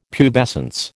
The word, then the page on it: pubescence: American English